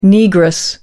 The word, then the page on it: negress: American English